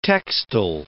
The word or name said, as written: textile